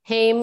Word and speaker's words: Haim: And then only in the past 2 years in my 30s have I been like, no, it's actually Haim like name. You know, that's what it rhymes with.